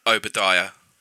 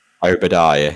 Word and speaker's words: Obadiah: Obadiah. – Obadiah.